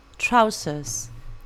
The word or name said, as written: trousers